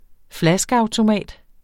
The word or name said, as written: flaskeautomat